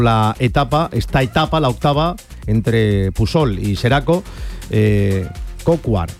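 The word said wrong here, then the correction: Bryan Coquard: Brian Cocard, que es precisamente el que está hablando, que imagino que no sé si se le entiende o no.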